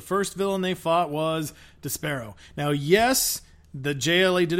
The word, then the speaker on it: Despero: Martian Manhunter, we go with Despero.